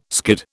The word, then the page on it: skid: British English